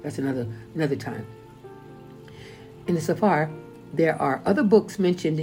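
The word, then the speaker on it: Sephar (ספר): And I know in my Sephar, many people pronounce it Sefer, or some people call it a Jewish Bible. No, excuse me, Hebrew Bible, they call it. But it's Sephar.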